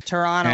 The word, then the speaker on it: Toronto: And I had this conversation on Sunday on our live show where I went into one of the groups for Toronto. See, we don't pronounce the T. – Toronto.